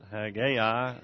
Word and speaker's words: Haggai: The way I pronounce it, Haggai, and has a different spelling in the Hebrew there.